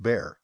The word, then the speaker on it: Baehr: B-A-E-H-R, but pronounced Bear. Tommy Bear. I know a guy named Daryl Dogs. D-A-U-G-S.